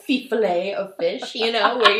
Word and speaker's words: Fifolet: So it's spelled F-I-F-O-L-E-T. So I was like, Fifolette. Fifolette. Like, Fifle the mouse. – Uh-huh. – But smaller. So yeah. – Fifolette. Which is untrue, totally untrue. So it's actually pronounced Fifolet.